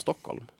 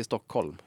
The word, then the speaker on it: Stockholm: Men jag tror jag, Stock, jag säger Stockholm istället för Stockholm. – Ja Stockholm. just det. Stock, ja just det, du har en annan betoning.